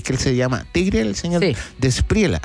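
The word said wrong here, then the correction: De la Espriella: De la Spriella.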